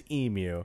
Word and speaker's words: emu: And the original and the Australian pronunciation is emu. – Huh. – It is emu. – Mm-hmm. – Apparently, some Americans started saying emu. – Forget Americans. – And it is listed as a standard U.S. pronunciation in reference works such as the Merriam-Webster and Oxford English Dictionaries.